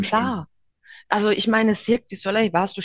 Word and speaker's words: Cirque du Soleil: Cirque du Soleil, ja, genau.